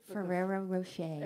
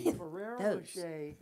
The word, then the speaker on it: Ferrero Rocher: Ferrero Rocher. – Exactly. Ferrero Rocher.